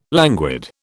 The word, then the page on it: languid: British English